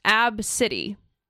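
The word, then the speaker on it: ABCDE: So the name is pronounced Ab-City.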